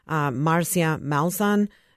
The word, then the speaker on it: Marcia Malzahn: Yes, they can find it either on Amazon just by typing my name, Marcia Malzahn. – And how do you spell that? – M-A-R-C-I-A. M-A-L-Z-A-H-N.